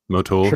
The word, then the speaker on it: Motul: I switched from Sweepco to Motul? Is that how you say it? – Motul, sure.